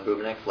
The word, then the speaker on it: Bubenik: My name is Christine Chan, and the last name is spelled C-H-A-N. – Thank you. – Tyler Bubenik, licensing investigator with the City of Cambridge, B-U-B-E-N-I-K.